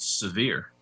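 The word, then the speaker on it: Sahvir: There was a time when I was hearing his name pronounced Severe Wheeler. Did he change his name too? – As far as I know, it's Severe. That's how I heard John Calipari say it after the game.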